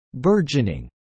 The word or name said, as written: burgeoning